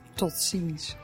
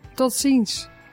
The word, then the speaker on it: tot ziens: Tot ziens. – Teach me how to say it. – Tot ziens.